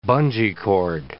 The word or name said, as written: bungee cord